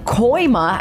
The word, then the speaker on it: Kooima: I am joined in the studio from Hope Haven Marketing Manager Brooke Koima. I said that right, right? – Yep, that's correct.